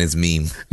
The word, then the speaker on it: meme: How do you, how do you pronounce M-E-M-E? – Meme. Nah, I'm just playing. It's meme. – Meme Oz. – Meme Oz. – It is pronounced meme. I've heard people say meme, meme, mem. It's meme. – Yeah. – It's always been meme.